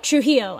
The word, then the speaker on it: Trujillo: Trujillo? – Trujillo. I don't know why it's so hard for me to say Trujillo.